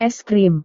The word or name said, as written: es krim